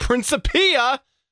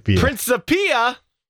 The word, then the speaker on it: Principia: titled Principia, is that how you say it? Principia. Principia.